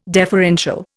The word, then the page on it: deferential: Indian